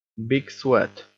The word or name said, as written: big sweat